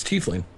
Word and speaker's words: tiefling: It's tiefling.